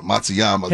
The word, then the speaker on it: Matsuyama: Yeah, Matsuyama.